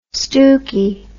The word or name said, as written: stookie